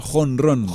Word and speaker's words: Honron: Se llama Honron. – Honron, perdón. Honron. O sea, lo he dicho mal en directo. – Lo digo en directo, pero igual vale la pena contar que es como home run, que es cuando hace uno está jugando béisbol y le da tan duro a la bola que la bola sale fuera del campo.